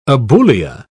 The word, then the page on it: aboulia: British English